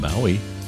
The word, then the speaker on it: Bowie: Who apparently, I learned recently, actually pronounced his name Bowie. David Bowie. Well, at least that's how they pronounce it in Britain.